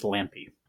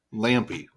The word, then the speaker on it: Lampe: I can't tell you anything else about the broadcast as we do not have the express written consent of Pac-12 Baseball, but it is pronounced Lampe. – Lampe.